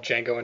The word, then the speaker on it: Django: Yeah, the movie's called Django Unchained. Mm-hmm. The D is silent.